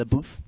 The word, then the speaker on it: LaBeouf: Shia LaBeouf.